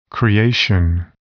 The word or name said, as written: creation